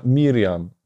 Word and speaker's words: Miriam: po hebrajsku Wiemy, że to się wymawia Miriam po prostu.